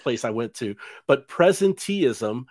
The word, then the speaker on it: Presenteeism: I totally love that term, by the way, and I apologize, I mispronounced it. Presenteeism.